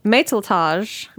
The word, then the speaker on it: Matelotage: Matelotage, as it's pronounced in French.